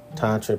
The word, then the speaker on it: Tantra: Tantra, pronounced Tantra with a sound in the first syllable, is a Sanskrit term that translates to weave.